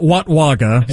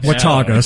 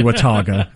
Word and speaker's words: Watauga: It's not Watwaga. Watauga. Sorry, Watauga.